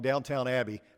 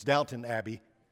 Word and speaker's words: Downton Abbey: I'm going to say this wrong, Downtown Abbey. I was told after the first service it's Downton Abbey, so sorry, but I'm, I'm English and I speak like a, West Texan.